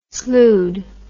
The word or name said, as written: sloughed